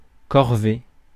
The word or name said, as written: task